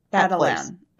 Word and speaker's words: Attilan: How do you say the A-T-T-I-L-A-N? – Adelan. Adelan. – Yeah, that's what Adelan.